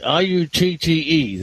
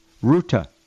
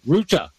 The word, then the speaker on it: Rutte: Rutte. There we go. NATO Chief Rutte. Again, it's pronounced Ruta. – Ruta.